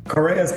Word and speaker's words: Correa: Please welcome head of the automotive and mobility sector of Bain & Company, Pedro Correa. Did I say that right? – Correa's perfect. Yes.